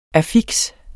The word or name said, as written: affiks